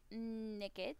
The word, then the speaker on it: Nickit: So far, I really like Nickit.